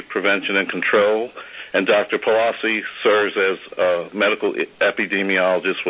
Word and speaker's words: Paulozzi: Frieden, that's spelled F-R-I-E-D-E-N. Following his remarks, Dr. Frieden will be joined by Dr. Leonard Palazzi, that's spelled P-A-U-L-O-Z-Z-I,